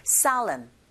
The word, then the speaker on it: solemn: 여기에 강세 주시고 그 다음에 S-O-L-E에서 강세가 O에 있거든요. 철자 O에 강세가 갔기 때문에 O보다는 I에 가깝게 발음이 들리고 그렇게 발음하시면 돼요. Solemn, solemn.